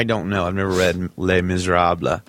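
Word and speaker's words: Les Misérables: In the novel Les Miserables. Is that how you say that? – Wow. Yes, it's actually absolutely how you say it.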